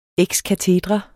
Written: EX

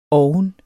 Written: oven

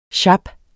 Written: sjap

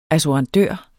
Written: assurandør

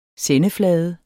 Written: sendeflade

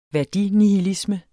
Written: værdinihilisme

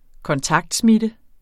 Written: kontaktsmitte

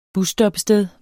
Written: BUSSTOPPESTEDER

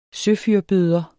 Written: søfyrbøder

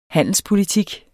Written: handelspolitik

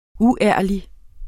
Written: uærlig

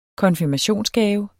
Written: konfirmationsgave

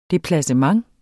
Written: DEPLACEMENT

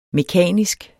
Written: mekanisk

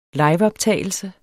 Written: liveoptagelse

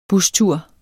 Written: bustur

